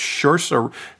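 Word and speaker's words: Saoirse: And it's Shursa. How do I say her name? Shursa. – Uh? Um, Shursa? like uh? inertia.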